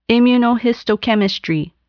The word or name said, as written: immunohistochemistry